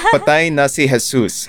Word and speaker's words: Patay na si Jesus: Patay na si Jesus. Am I saying that right? – Sort of, sure. yeah? – yeah, correct. – Passable. – that is correct.